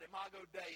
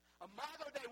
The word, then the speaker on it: Imago Dei: Imago Dei is how you say it in Latin. Listen, Imago Dei, we were made in the image of God.